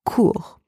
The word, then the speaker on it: cour: The first word, la cour, spelled C-O-U-R, mean either courtyard or playground or even court, as in tribunal. The letter C in that word is pronounced K, and the letters O and U together are pronounced OU, and then the final R in that word is not silent, so it is pronounced. In the second word, le cours, spelled C-O-U-R-S, can mean a few different things, but mainly means class or lesson.